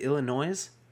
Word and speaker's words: Illinois: Is the S silent? Is it Illinois? – The S is silent.